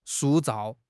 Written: 塑造

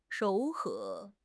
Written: 柔和

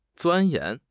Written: 钻研

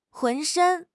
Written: 浑身

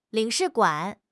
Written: 领事馆

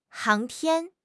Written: 航天

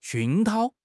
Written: 熏陶

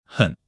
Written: hen3

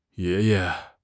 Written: ye2 ye5

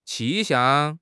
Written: 气象

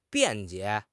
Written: 辩解

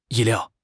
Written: 意料

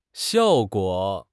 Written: xiao4 guo3

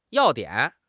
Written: 要点

